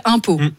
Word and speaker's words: Umpeau: Non, parce que la ville s'appelle Impau. – Ça s'écrit comment? Ça s'écrit comme les impôts qu'on paye? – Non, ça s'écrit U-M-P-E-A-U. – Ah bah voilà, on embrasse les habitants d'Impau.